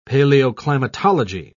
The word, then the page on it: paleoclimatology: British English